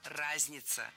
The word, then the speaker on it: разнятся: Но до сих пор во всех словарях в этом глаголе ударение падает только на первый слог. Во всех формах. Разнюсь, разнишься, разница, разнимся, разница. Прошу запомнить.